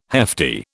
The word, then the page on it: hefty: British English